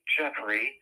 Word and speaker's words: Geoffrey: It's called Psychic Geoffrey.